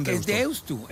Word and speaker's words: Deusto: Deusto. – ¿Qué haces con Deusto? – Es Deusto, porque se escribe con U final.